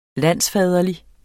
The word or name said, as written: landsfaderlig